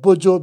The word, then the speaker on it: Bojo: That's on the other side of Accra, Weja area, called Bojo Beach. That's spelled B-O-J-O Beach.